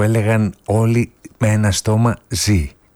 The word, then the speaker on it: Ζ: «Ζήτα». Βέβαια η πρόθεση των δημιουργών της ταινίας ήταν το «Ζήτα» του τίτλου να προφέρεται «Ζή» και όχι «Ζήτα».